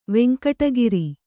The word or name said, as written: ವೆಂಕಟಗಿರಿ